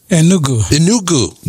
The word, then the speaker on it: Enugu: Enugu, Nigeria.